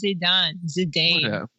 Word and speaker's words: Zidane: It's Zidane.